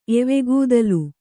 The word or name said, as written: ಎವೆಗೂದಲು